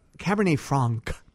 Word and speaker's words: Cabernet Franc: Now, I know, now your Cabernet Franc Am I saying that right? – Yes.